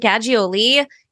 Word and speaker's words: Gaggioli: So I'm Lauren Gaggioli. You can say it like ravioli and you have to use your hand if you're going to get the pronunciation correct.